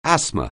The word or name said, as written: asthma